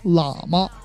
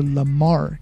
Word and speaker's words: Lamar: 好吧，这个歌手 Kendrick Lamar，如果你们喜欢他的歌，可以去网易云音乐或者其他的平台去搜，注意一下他的名字，很多人把他读作 Kendrick 喇嘛，不是喇嘛，跟喇嘛没有鸡毛关系，是 Lamar， Kendrick Lamar。